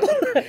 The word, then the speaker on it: Bula: So we'll just start with the word Bula for today, which is, no, it's not Bula, but Bula.